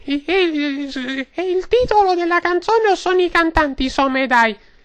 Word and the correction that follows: Someday: Innanzitutto, sindachessa, Someday. – E appunto, io, ecco, lei mi sottolinea la perfetta pronuncia, io Somedai.